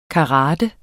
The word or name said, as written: karate